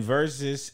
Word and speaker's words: Verzuz: And then versus is spelled V E R Z U Z.